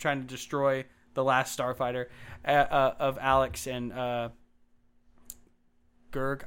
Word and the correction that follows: Grig: I'm going to mispronounce these guys' names. Grig. – Grig.